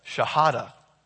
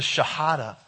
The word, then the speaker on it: Shahada: They have to recite the Shahada. Did I say it right? Do you guys know this? I did? Oh, good. And this is what that is, the Shahada.